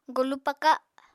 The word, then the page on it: golu paka: Apatani